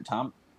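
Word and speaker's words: Tomi: Tommy. – The joke for so long has been mispronouncing her name that I can't actually remember what her name is, because people call her Tammy as a joke.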